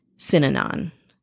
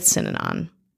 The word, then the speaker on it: Synanon: We're talking about the original West Coast insane fucking cult posing as sober wellness, Synanon. And I hope I'm saying that right. Some people say Synanon, some people say Synanon. I'm going to go with Synanon.